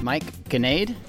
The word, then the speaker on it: Ganade: I asked you how to say your name just a minute ago, and I still, like, part of me wanted to say Nade, even though it's Ganade.